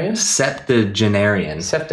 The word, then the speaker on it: septuagenarian: Septuagenarian.